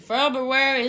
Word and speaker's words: February: February is Black History Month. – Yes, I went out of my way to pronounce the R.